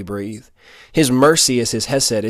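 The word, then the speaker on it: hesed (חסד): You may say it as hesed, you know, trying to do the Jewish, you know, mouth thing. I can't really do it. But that's how it's said, hesed, and it means God's covenant kindness, his mercy.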